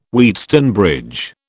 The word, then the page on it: Wheatstone bridge: British English